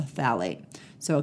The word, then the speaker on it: phthalates: This one's spelled P-H-T-H-A-L-A-T-E-S. So it's not how it sounds. There's a P-H before it, but it's pronounced phthalates.